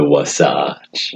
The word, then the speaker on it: Wasatch: Wait, how do you say it? – The Wasatch. – Wasatch. – Yeah. – Sasquatch. Wasatch. Oh, all right.